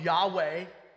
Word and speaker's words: Yahweh: Over time and by choices by Bible translators, and not necessarily Bible translators, just by scholars, we've landed on Yahweh being the pronunciation of the word that's used here.